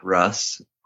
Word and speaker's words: Russ: Well, my name is John Clark Russ. Clark spelled with an E at the end of it. Russ is R-U-S-S.